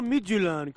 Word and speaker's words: Midtjylland: Eguerdión, Susana, lo primero que te digo es felicitarte porque qué bien has pronunciado Midtjylland.